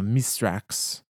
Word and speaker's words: Mithrax: And so the Eliksni pronunciation of his name, you know, Mistrax, Shear took and interpreted as Mithrax, and it is just stuck ever since.